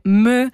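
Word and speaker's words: MØ: Una collaborazione molto rappresentativa del 2016, Major Lazer insieme a Justin Bieber e Me, Cold Water, sì, si pronuncia proprio così, Me, perché è scandinava, adesso non mi ricordo più se è svedese o vabbè, comunque in quella zona d'Europa.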